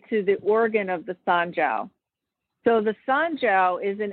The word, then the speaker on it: Sanjiao: You also talk about the Sanjiao, if I'm saying that right. J-I-A-O, yep. – Sanjiao.